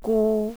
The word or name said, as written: gu